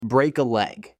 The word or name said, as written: Break a leg